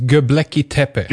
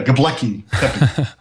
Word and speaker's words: Göbekli Tepe: I think it's Göbekli Tepe. – Göbekli, yeah, Göbekli Tepe.